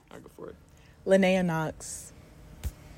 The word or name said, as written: Leonaya Knox